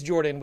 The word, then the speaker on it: Jorphdan: My name is Jordan with a silent PH in the middle, and I'm joined always by my wonderful co-host, Sir Lucian, over at Sir Lucian Gaming.